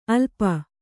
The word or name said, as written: ಅಲ್ಪ